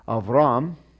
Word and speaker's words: Abram: Now Terah lived 70 years and begot Abram, Noah, and Haran. Okay, so Abram, we're going to pause there because he's the important guy. And again, there's no dot in there, so you might pronounce it Avram.